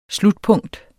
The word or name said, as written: slutpunkt